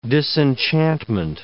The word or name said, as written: disenchantment